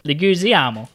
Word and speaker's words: Leguizamo: John Leguizamo. Leguizamo. – Golami. – Golami. Come on. – Leguizamo. Leguizimi? – Spaghetti.